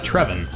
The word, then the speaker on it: Trevin: Our first one comes in from Trevin, which rhymes with Drevin.